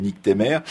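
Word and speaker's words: Nycthémères: Le plus drôle, évidemment, par son nom, c'est Nick Temer. C'est un blog qui ça s'écrit NYCTHEMERES, et ça s'appelle également Mesure du rap.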